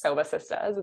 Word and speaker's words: Sobah Sistahs: And I love the way that's pronounced, Sobah Sistahs.